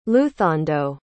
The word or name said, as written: luthando